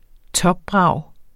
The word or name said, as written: topbrag